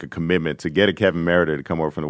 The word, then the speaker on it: Merida: My question for you, and clearly you wanted to be a part of that, and now I think Kevin Merida, am I pronouncing his name right? – Yes.